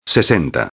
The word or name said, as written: 60